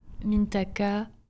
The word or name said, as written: Mintaka